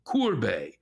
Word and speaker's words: Courbet: But where do you get off, not knowing that the et or et at the end of the French names is normally pronounced a? Say it 10 times. Courbet, Courbet, Courbet, Courbet, Courbet, Courbet, Courbet, Courbet, Courbet, Courbet.